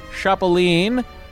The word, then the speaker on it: Chapeline: Brian Stromitz, Catherine Gertner, Cedrin Law, Chuck Credo, Daniel Permit, Daniel St. Louis, David, Ed Mundy, El Chapeline. I'm going to read everything. Like trampoline, with a hard CH. Oh, so it's Chapeline. I see, like Chaplin, stressing the accent syllable, Colorado.